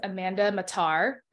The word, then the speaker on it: Amanda Mattar: And today I have a special guest, Miss Amanda Mattar.